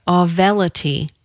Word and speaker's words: Auvelity: The drug is called, it's brand new, it's called, let's see if I can say it right, Auvelity, A-U-V-E-L-I-T-Y. Remember the A-U-V.